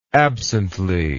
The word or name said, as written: absently